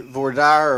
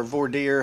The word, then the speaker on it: voir dire: during jury selection, each attorney has the option to voir dire or voir dire, however, you know, it depends on whether you're Yankee or not, how you pronounce it.